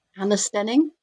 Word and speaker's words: Anna Stenning: Anna Stenning.